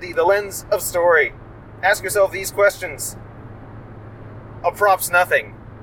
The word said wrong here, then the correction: apropos: I think that word is apropos. – Apropos nothing? – Yes. It does have the S in everything, but it's pronounced apropos.